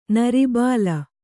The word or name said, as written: ನರಬಲಿ